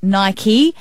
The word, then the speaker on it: Nike: There's obviously been so much confusion surrounding that the co-founder has actually come out and opted for the two-syllable pronunciation, Nike, instead of Nike, because he's like, guys, I get asked about it all the time. You say it Nike, not Nike.